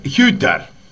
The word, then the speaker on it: Hütter: Se escribe Uter con la U. Con la U con diéresis, sería una I. – Uh-huh. – Así que sería Hütter. Hütter, porque es con H además, ¿no?